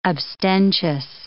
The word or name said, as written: abstentious